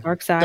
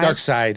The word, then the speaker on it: Darkseid: Is that how you say his name? – Yeah. – Darkseid. – Darkseid. Yeah, it's weird. They spelled it weird.